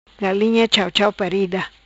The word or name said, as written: Galinha Chauchau Parida